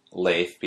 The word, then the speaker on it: Leif: It's like the clap, Leif, like one syllable, people.